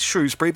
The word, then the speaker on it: Shrewsbury: Shrewsbury then, and it is Shrewsbury because in July 2015, Shrewsbury was voted the winning pronunciation of Shropshire's county town at a charity debate hosted by the University Centre in Shrewsbury.